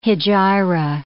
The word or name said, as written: hegira